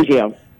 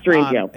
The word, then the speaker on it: Strangio: As we talk about the word immutable, which is really important in the context of civil rights, how do you pronounce it? Is it Strangio? I'm not sure. I'm not being disrespectful. – Strangio. – Strangio. Okay. – Yes, Strangio.